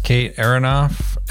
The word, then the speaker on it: Kate Aronoff: Today we've got on the podcast Kate Aronoff.